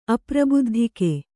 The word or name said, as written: ಅಪ್ರಬುದ್ಧಿಕೆ